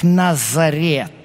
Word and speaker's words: Назарет: Отсюда название города Нацерет. Но так как в греческом языке нет буквы «ц», то они его произносят как «Назарет».